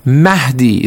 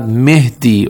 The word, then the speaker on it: Mahdi: For example, it is Mahdi. It is not Mehdi or Mehdi or something like that.